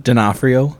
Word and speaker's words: D'Onofrio: How do you say his name? – D'Onofrio. – Yeah, I can never say it right.